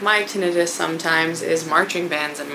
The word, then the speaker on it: tinnitus: But one of the most common symptoms to pay attention to is tinnitus. Yeah, it's pronounced tinnitus, not tinnitus. Common mistake.